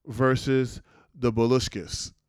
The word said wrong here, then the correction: Bolsheviks: Whatever you say. – The Bolsheviks? – Yeah, the Bolsheviks.